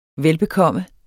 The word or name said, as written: velbekomme